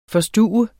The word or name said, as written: FORSTUVEDE